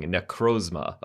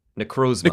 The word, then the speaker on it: Necrozma: How do you pronounce this thing? Necrozma. Oh, we've been saying it right. Necrozma.